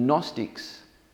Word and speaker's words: Gnostics: The Gnostics, with it's a silent G in English, were teaching a whole range of things that are very, very popular today.